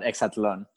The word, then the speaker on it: Exatlon: Yeah, the show is called Exatlon.